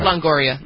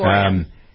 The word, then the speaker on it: Longoria: By the way, just as an aside, I thought it was interesting that Eva Longoria, is that how you pronounce Longoria? Sorry. – Longoria. – Mm-hmm. Longoria.